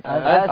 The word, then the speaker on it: ث: أث. – يبقى لولا الهمسة الذي في الثاء لكان الذال، ولولا الترقيق الذي في الثاء لكان الظاء، يبقى اهي أث أث، ولا تنطق الثاء سين هكذا، ودايما تتصبحوا بي وتتمسوا بي، يرفعكم الله أعلى الدرجات.